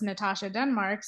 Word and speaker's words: Natasha Denmark: It's Natasha Denmark.